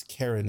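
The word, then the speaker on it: Keren: I'm going to butcher his name or her name, but Shavit Karen. It's Karen. It's K-E-R-E-N.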